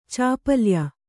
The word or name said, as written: ಚಾಪಲ್ಯ